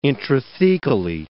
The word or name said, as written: intrathecally